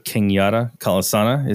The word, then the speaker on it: Kenyatta Kalisana: We have Kenyatta Kalisana.